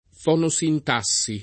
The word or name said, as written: fonosintassi